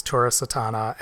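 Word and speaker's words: Tura Satana: But, like, the other big star out of this movie would be the lead character, Varla, who's played by, is it Tura Sitana? – I believe that's how you pronounce it, is Tura Sitana, and you...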